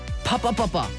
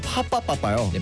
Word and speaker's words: Pop Off: 요 제목 발음이 좀 어렵잖아요. – 그쵸. – 그래서 뭔가 키키 선배님들께서도 연구를 해봤는데 파빠빠빠 라고 부르면 된다고 하시던데요. – 아, 파빠빠빠요? – 네, 파빠빠빠 파빠빠빠.